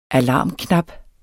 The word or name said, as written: alarmknap